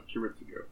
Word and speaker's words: Kiritsugu: Kiritsugu. – Yes, that was Kiritsugu. – Kiritsugu, yeah, okay.